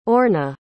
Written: orna

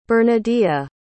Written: bernadea

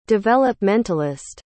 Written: developmentalist

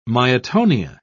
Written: myotonia

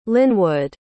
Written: lynwood

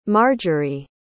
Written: margery

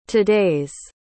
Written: todays

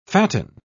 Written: fatten

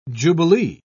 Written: jubilee

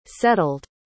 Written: settled

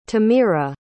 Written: tamira

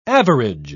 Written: average

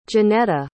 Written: Janetta

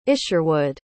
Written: isherwood

